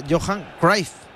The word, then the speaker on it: Cruyff: Pero Quique Guas siempre pronunciaba Johan Cruyff. ¿No? – Cruyff. – Lo pronunciaba como teóricamente lo pronunciaban los holandeses. Todos decíamos Cruyff y él era Johan Cruyff.